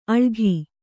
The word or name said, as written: ಅಳಘಿ